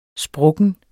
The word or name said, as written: sprukken